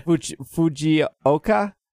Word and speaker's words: Fujioka: And our director, Kaname Fujioka. – Okay. – Fujioka.